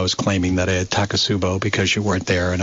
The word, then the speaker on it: Takotsubo: And what is it called again? I pronounce it wrong. – Takotsubo. – Takotsubo, yeah. Which is Japanese.